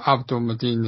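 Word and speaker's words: Abdul-Mateen: But yeah, I just thought, I mean, like, going back to the casting, I'm going to get his name wrong, but is it Yahya Mudeen, is it? – Yeah, Abdul Mateen the second.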